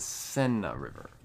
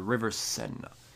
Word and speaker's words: Seine: I may have butchered the Seine River. It's the Seine River. The River Seine. That's how they pronounce it in French, I think. I'm pretty sure.